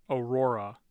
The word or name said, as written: aurora